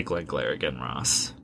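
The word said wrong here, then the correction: Glengarry Glen Ross: You have to pronounce it correctly. – I can never pronounce it correctly. – Well, if you don't pronounce it correctly, you don't get credit for it. – I am a mush mouth. Glenn Gary Glenn Ross. – Perfect score.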